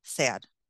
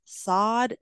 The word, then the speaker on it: Sade: And some say that it's pronounced sod and some say sad, but I go with sad because it's easier for me to remember.